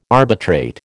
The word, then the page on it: arbitrate: American English